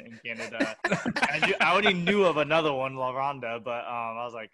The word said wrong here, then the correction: La Ronde: I probably totally said that wrong too. But yeah, you did. It's okay though. – It's like La Ronde. – La Ronde. – La Ronde. – Sorry, you have to say like a rumble in your voice.